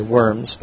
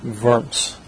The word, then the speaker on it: Worms: Remember that in German the W is pronounced as a V, and so we wouldn't say Worms, but Worms.